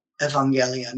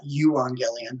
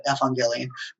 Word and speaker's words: evangelion: The prefix epsilon upsilon, the EU, it's pronounced like ev in Greek, evangelion, not euangelion, but evangelion.